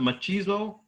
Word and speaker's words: machismo: And like, I'm surprised more Hispanics don't like him because he has that, what's that, machismo?